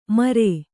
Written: ಮರೆ

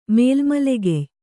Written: ಮೇಲ್ಮಲೆಗೆಯ್

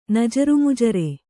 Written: ನಜುರುಮುಜುರೆ

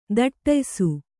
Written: ಥಟ್ಟಯಿಸು

